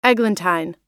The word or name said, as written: eglantine